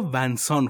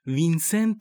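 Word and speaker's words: Vincent: تو فارسی همه جا ونسان خونده میشه و انگار وینسنت تلفظ درست‌تریه و ما انگار بین ونسان و وینسنت باید نوسان داشته باشیم.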